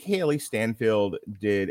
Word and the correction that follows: Steinfeld: Um, Steinfeld. Steinfeld. – Uh, Stein, like beer Steinfeld, I think is how it's it.